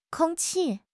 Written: kong1 qi4

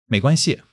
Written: mei2 guan1 xi5

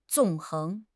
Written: 纵横

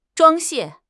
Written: 装卸

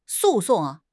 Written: 诉讼

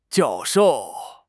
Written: jiao4 shou4

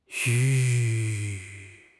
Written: yu3

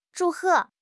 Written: zhu4 he4

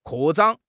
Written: 扩张

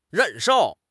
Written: 忍受

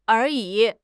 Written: 而已

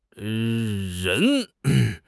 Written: ren2

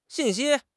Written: xin4 xi1

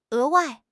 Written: 额外

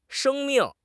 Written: sheng1 ming4

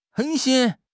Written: 狠心

